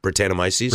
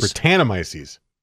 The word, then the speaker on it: Brettanomyces: Brettanomyces? – Brettanomyces? – Brettanomyces. I was so close. – Brett. You can just call it Brett. – Brett. The beer on the label said with Brettanomyces.